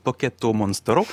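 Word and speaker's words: Pocketo Monstro (ポケットモンスター): Alors à l'époque, ça s'appelait Pocket Monsters en fait au Japon, avec la prononciation japonaise, ça donnait plus ou moins Pokémon Pocketo Monstro, si je me souviens bien. – Vous le faites très très bien.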